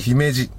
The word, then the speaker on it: 姫路: 最後になりましたが、姫路のアクセントは、地元ではキノコのしめじではなく我らがクラゲさんと一緒のクラゲのアクセントですよ。 – 姫路、姫路。